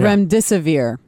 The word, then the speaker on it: Remdesivir: How do you say it? Remdesivir? – Yeah. Remdesivir.